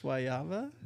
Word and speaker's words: guayaba: Guayaba? – That's just how I hear the Puerto Ricans say it. – In Spanish, that's how you say it.